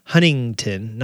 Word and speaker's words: Huntington: I always say Huntington, not hunting. So I say if it was Tunnington, I'd say the first T and the last T, but apparently we don't pronounce the middle T.